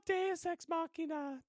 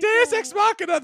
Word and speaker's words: Deus Ex Machina: Yes, the Deus Ex Moxica. Whatever. However you pronounce it, I remember you. – It's called Deus Ex Machina. There you Deus Ex Machina, that's it.